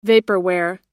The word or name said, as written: vaporware